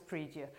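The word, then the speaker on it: pridie: This is, apparently, somebody who speaks Latin has told me that this is pronounced as praedia.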